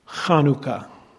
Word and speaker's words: Chanukah: It is pronounced like this, Chanukah. That is the Hebrew word for dedication. The Feast of the Dedication, or Chanukah. By the way, the proper way is with the cha at the beginning.